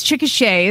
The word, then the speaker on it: Chickasha: It looks like Chickasha, but it's Chickasha is how you pronounce it here.